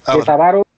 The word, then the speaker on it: Avaro: Y una, delegación sale de la ciudad, va en ella o la dirige un TAC a, Ávaro o Avaro, no sé si lleva acento en la, si es llana o brújula. – No lleva, o sea, lo que me no lleva, no tiene acento. – Avaro.